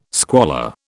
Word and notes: squalor: British English

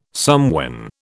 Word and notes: somewhen: British English

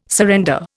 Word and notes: surrender: Indian

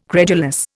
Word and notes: credulous: Indian

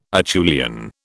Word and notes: Acheulian: British English

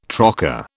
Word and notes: trocar: British English

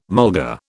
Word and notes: mulga: British English

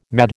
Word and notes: mad: American English

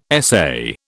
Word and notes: essay: British English

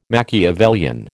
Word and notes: machiavellian: American English

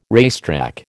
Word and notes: racetrack: American English

